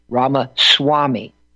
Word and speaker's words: Ramaswamy: The accented syllable is the swa, S-W-A-H, Ramaswamy.